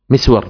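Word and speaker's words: مِسْوَر: وهكذا الثوري والتوزي، الثوري والتوزي، ومسور ومسور، مسور بكسر الميم مع إسكان السين، ومسور بضم الميم وفتح السين وتشديد الواو، مسور.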